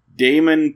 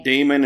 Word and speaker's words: Daimon: Damon Prack. Okay. But Damon is spelled D-A-I-M-O-N.